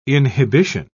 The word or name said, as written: inhibition